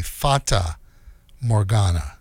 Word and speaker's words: Fata Morgana: Lorraine before that, L'Rain, with Soulless Cycle from the new album Forta Morgana. Did I say that right? Let's see. No, Fata, F-A-T-A, Fata Morgana.